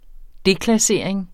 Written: deklassering

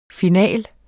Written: finale

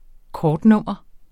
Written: kortnummer

